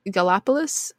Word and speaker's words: Gallipolis: The traffic lights had been malfunctioning all day, causing delays on the Silver Bridge, which connected Point Pleasant with Gallipolis, Ohio, which is how you say it.